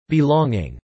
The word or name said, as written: belonging